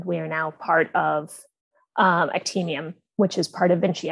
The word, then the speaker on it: Actemium: So this is Actemium? – Yes. – That's how you say it.